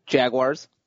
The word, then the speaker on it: Jaguars: How do you say it? – Jaguars. – Nice. – Okay. Well done.